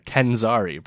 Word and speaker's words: Kenzari: Kenzari, bro.